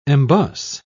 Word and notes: embus: British English

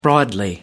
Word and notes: broadly: American English

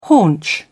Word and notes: haunch: British English

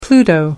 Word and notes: pluto: American English

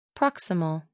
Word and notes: proximal: American English

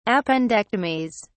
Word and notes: appendectomies: British English

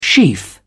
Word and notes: sheaf: British English

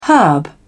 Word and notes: herb: British English

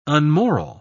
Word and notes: unmoral: British English

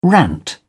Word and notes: rant: British English

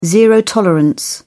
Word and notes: zero tolerance: British English

